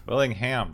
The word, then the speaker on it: Willingham: I hope I'm pronouncing that right. I think it might be Wilhelm, but it's got an N in the middle of it, so I don't know if I'm supposed to pronounce that. – Willingham? – Willingham, there we go.